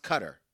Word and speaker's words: Qatar: It is pronounced Qatar.